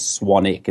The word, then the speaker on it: Swanwick: Yeah, my last name is spelled Swanwick. Right. So it's Swanwick. But the correct pronunciation is Swanick. It's the silent W. Just, you know, like Warwick. The name Warwick, it's Warwick.